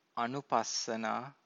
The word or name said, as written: anupassana